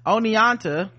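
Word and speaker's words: Oneonta: It's pronounced Oniata. Oh wow, I was kind of close. Okay, Oniata,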